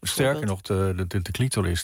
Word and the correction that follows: clitoris: Die, clitoris, sorry. – Ja, het is altijd die klemtoon.